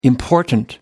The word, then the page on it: important: American English